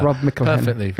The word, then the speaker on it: Rob McElhenney: Rob McElhenney.